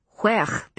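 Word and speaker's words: wearh: I'd never heard the word huech before, with its whistling W at the beginning and guttural sound at the end.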